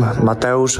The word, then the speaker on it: Mateusz: I'm Mateusz Rembetski.